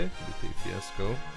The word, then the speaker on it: Lupe Fiasco: Lupe Fiasco. – Lupe Fiasco.